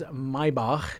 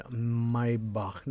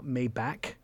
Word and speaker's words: Maybach: It is a brand new Mercedes Maybach GLS 600. Maybach, not Maybach. – Maybach. – Most Americans butcher it.